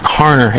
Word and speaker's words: Harner: My name is actually Matt Harner, H-A-R-N-E-R.